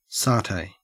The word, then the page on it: satay: Australian English